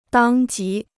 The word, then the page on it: 当即: dāng jí